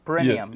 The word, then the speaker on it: perineum: Perineum.